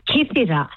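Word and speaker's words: Kythira: I said Kithira. Hang on. How do you say the island again? – You said it right. Kithira.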